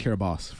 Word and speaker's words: Kiribati: And Kiribati is actually pronounced Kiribati, for anyone who's wondering.